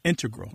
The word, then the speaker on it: integral: Yeah, but both correct, just to clarify here, both correct, but the mathematicians and math-using scientists are more likely to say integral with the stress on the first syllable.